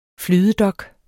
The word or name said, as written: flydedok